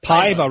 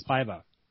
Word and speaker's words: Paiva: Bonus awards, Holly and Pava, Well, I can't remember how to say his name. – Paiva. – Paiva, right. It's Paiva. It's like Rocky Paiva.